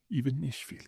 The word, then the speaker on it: Ivanishvili: In the case of Georgia, that guy's name is, let's see if I can get this right, Bidzina Ivanishvili. Ivanishvili, yeah. You have to have five syllables to be a good Georgian name.